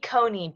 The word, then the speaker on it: Coney: Isn't it Amy Cohen Barrett? – Let me Google it, because I swear I said it wrong. I said it wrong on the last podcast, I think. I think it's either with Amy Coney Barrett. – Coney. Okay, Coney. So I've been saying Comey.